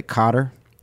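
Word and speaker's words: Koetter: Is that how you pronounce it? Cotter? – Yes.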